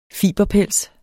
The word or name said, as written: fiberpels